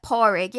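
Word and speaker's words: Pádraig: You don't pronounce the D. It's like Porrig, yeah.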